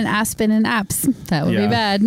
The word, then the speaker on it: apse: Am I pronouncing it right? APSE? – Yeah. Apse. – Yeah. Yeah. Mm-hmm. – I thought I put the definition. – Not to be mistaken for an asp.